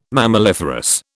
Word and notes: mammaliferous: British English

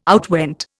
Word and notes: outwent: Indian

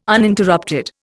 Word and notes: Uninterrupted: Indian

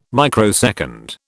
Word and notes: microsecond: British English

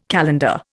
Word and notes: calendar: Indian

